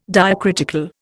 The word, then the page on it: diacritical: Indian